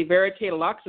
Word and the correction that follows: Vert Lux: It's actually Vert Lux, and it's French for green luxury.